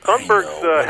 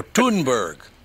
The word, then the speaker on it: Thunberg: Wouldn't her name be Thunberg? You wouldn't pronounce the H, would you? – That's probably right. – So Thunberg.